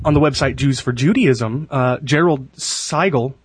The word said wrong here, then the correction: Siegel: I think is might be how you pronounce his name. – I think it's Siegel. – Siegel.